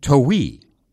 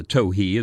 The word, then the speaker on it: towhee: The name towhee is supposed to be derived from the call notes of this bird, and therefore should be pronounced, probably, towhee.